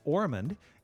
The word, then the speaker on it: Ormond: It's C-E-V-I-N. Ormond is just like Osmond, only with an R instead of an S. So it's O-R-M-O-N-D.